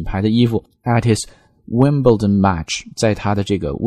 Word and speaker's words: Wimbledon: 温布尔登这个词在读的时候要把它读成 Wimbledon， Wimbledon，其实不是 Wimbledon 而是 Wimbledon 啊，这个 L 音要稍微出来一点才好听，